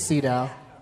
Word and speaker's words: Sydow: Or Sydow. Oh. I've never known for sure how you pronounce it. I think it's Sydow. Sydow? – Yeah.